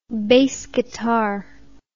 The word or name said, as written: bass guitar